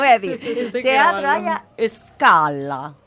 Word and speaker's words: Scala: Que sepáis que se, yo le diría Scala, pero me ponía entre paréntesis cómo se pronunciaba en italiano. Y se pronuncia Scala.